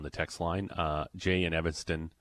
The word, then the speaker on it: Evanston: Evanston. – Yeah. – At least I said that right. – Yes. – Evanston.